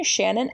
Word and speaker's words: Shannon: Just type in my name, Shannon, S-H-A-N-N-O-N Moran.